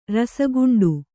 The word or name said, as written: ರಸಗುಂಡು